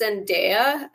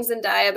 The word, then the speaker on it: Zendaya: And I think it's pronounced Zendaya. I know you've been saying Zendaya, but I'm pretty sure it's Zendaya.